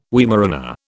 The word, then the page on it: Weimaraner: British English